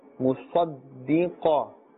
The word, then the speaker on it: مصدقا: Sad is part of the حروف مستعالية. Qaf is also part of the Huruf Musta'aliya, and therefore we're pronouncing them with a thicker tone, a full mouth. Musaddiqa.